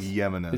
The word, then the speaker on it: Jimenez: It's like Jimenez. – It's Jimenez. – Yeah, J is pronounced Y.